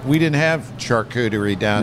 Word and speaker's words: charcuterie: This is it pronounced charcuterie? – Charcuterie. – Charcuterie. – At least I think so. – How do you say am I correct? – Charcuterie. Charcuterie. Is correct, yeah.